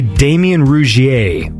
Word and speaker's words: Damien Rougier: So this is, I'm going to say Damien Rougier is how I'm going to pronounce his name. Damien Rougier. It's spelled R-O-U-G-I-E-R.